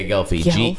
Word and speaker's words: Gelfi: Yeah, Gelfie. It's spelled G-E-L-F-I in when you read it. – Can I... – More like G-E-L-F-U.